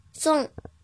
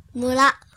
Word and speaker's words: 村: 音读的时候读作“ 送”，“ 送”，“ 送”。比如“ 村长”“ 送酒”“ 送酒”“ 送酒”。写成日语汉字是“ 村” 和。长的繁体字，村长。训读的时候读作“ 木拉”，木拉，木拉。